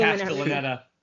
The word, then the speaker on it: Castellaneta: Dan Castellaneta. I can never pronounce his last name. – Castellaneta.